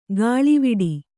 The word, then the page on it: ಗಾಳಿವಿಡಿ: gāḷiviḍi